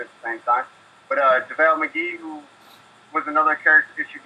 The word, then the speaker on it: JaVale McGee: If they're amnestying Blatch, who has, you know, character flaws, they got rid of McVale. How do you say his name? – JaVale McGee, yeah.